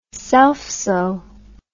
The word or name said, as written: selfsow